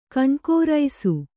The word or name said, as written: ಕಣ್ಕೋರೈಸು